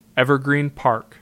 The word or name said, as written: Evergreen Park